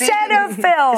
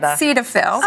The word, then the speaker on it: Cetaphil: Aquaphor because it's Cetaphil. – Is it Ceta? – Cetaphil. – Cetaphil. – I've been saying it wrong for 20 years.